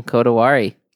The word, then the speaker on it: Kodawari: All right, Professor Joseph Labriola, welcome to Exploring Kodawari.